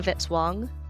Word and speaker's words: Vitz-Wong: Music by Kevin Vitz-Wong, rhymes with hit song.